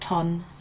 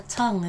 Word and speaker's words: Tongue: Our DJ always pronounces it ton. Like, no, Hannah, tongue.